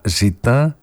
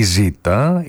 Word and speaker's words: Ζ: «Ζήτα». Βέβαια η πρόθεση των δημιουργών της ταινίας ήταν το «Ζήτα» του τίτλου να προφέρεται «Ζή» και όχι «Ζήτα».